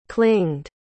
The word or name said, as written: clinged